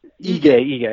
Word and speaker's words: Ige: Dan Ige, how do you say his last name again? – Ige. – I always forget that.